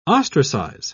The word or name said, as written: ostracize